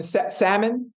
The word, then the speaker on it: salmon: And salmon? Salmon? The L is silent?